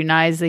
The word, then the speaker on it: Knies: So, and then there's Matthew Knies. – That's, you said it right. – Mm-hmm. Right. Yeah. Matthew Knies, he's a left winger, 19 years old, Arizona born.